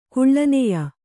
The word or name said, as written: ಕೀಳ್ನಾಯ್